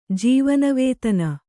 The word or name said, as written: ಜೀವನವೇತನ